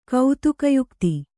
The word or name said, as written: ಕೌತುಕಯುಕ್ತಿ